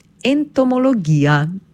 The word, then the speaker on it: entomologija: Pri izgovorjavi se osredotočimo na samoglasnik i, nad katerim pravopisno gledano napišemo ostrivec, zato pravilno izgovorjena beseda zveni tako. Entomologija.